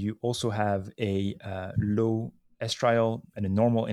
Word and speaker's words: estriol: And it has four components: alpha-fetal protein, beta-HCG, unconjugated estriol, and is that how we pronounce estriol, right? – Yeah.